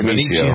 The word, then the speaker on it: Benicio: Martin Scorsese, Benicio del Toro, is that how you pronounce it? – Benicio.